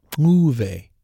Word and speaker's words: Muve: And yes, that's Muve. It's almost like a little kissy sound. That is intentional because that's how they pronounce it in Zimbabwe, which is where Sahini originally hails from.